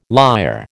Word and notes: lyre: American English

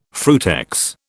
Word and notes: frutex: British English

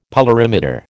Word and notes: polarimeter: American English

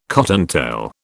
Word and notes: cottontail: British English

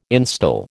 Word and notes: instil: American English

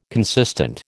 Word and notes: consistent: American English

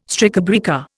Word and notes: strikebreaker: Indian

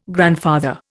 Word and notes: grandfather: Indian

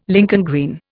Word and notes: Lincoln green: Indian

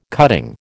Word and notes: cutting: American English

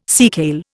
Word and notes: seakale: Indian

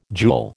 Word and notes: jewel: American English

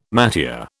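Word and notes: métier: British English

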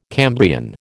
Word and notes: Cambrian: American English

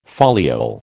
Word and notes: foliole: American English

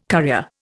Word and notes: currier: Indian

